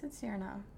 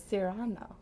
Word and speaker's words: Cyrano: We read the play in French in high school, and with that pronunciation, it was Cyrano.